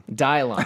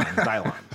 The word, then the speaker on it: Dylon: I pronounce it Dylon. – Dylon. – Dylon. – This one's for you, Dylon. The Commander Summit 2017 for Dylon Lori. – Oh, man. It rhymes with Cylon.